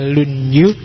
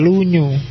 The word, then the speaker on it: Lunyu: Yang pertama judulnya Dasu, yang kedua Lunyu, bacanya misah ya, kalau disambung jadi Lunyu. Yo, kalau Lunyu licin.